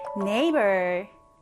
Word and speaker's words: neighbor: neighbor, neighbor입니다. 얘를 발음할 때 주의해 주셔야 돼요. 얘는 neighbor이라고 읽어요.